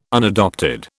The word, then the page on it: unadopted: British English